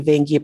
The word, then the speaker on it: Veiqia: And since about 2014, I've been a member of the Veiqia Project. That's spelled V-E-I-Q-I-A. And if you wish to pronounce it, it's say Vein, V-A-I-N, Gear.